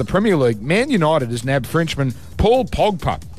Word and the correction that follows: Pogba: And I've pronounced that incorrectly. Paul Pogba. Thank you.